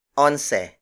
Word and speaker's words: onse: Gis pesos is spelled as D-I-Y-E-S space P-E-S-O-S. Again, The Tagalized Spanish word for 10 is gis. Up next is once. Once. Once. Once is spelled as O-N-S-E.